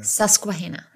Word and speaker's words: Susquehanna: Susquehanna.